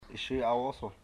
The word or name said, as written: Yshir awoso